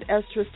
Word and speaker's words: Estra: Thank you for joining me, Estra, this July 27, 2013, for Talking About You with Estra on Blog Talk Radio and Estra's TV, currently featured on YouTube under Estra's blog. Make sure not to be diverted to another link. My official website is ESTRA, and that's pronounced Estra.